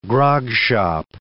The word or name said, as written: grogshop